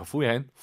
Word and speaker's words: 服务员: 이거 우를 빼고 한번 발음해 볼게요. 服务员.